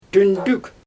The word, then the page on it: tyndyk: Xyzyl